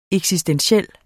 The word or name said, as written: eksistentiel